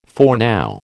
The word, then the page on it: foreknow: American English